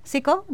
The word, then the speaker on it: Sicko: If you have ever watched this excellent documentary film, actually, by Michael Moore, Sicko is how you pronounce it? Sicko, right? Sicko, not psycho, Sicko.